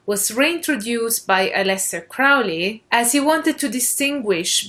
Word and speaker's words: Crowley: The term magic spelled with a K was popularized by the very famous esotericist Aleister Crowley, sometimes also pronounced as Crowley by American people, but I will stick to the British pronunciation Crowley since he was British.